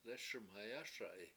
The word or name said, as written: lEES1mhayASa7i